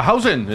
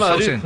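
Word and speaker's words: Huijsen: ¿Cómo se pronuncia este? Hunsen. – A Hausen. El central. Hausen.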